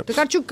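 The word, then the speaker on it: Tokarczuk: Ольга польская Токарчук или Токарчук, мне кажется, ага, Токарчук, более... Ну, давайте так. Правильно. Токарчук как такое, действительно. Такое ударение.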